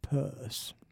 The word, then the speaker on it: Peirce: Peirce, it's spelled P-I-E-R-C-E. That's how he spelled his name. But he was from a well-to-do Boston Brahmin family, and they pronounced it Peirce.